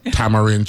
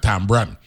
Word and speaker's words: tamarind: I'm going to pronounce it correctly, right? Tamarind tree. But we'd have said tambran. Right, the tambran tree, right.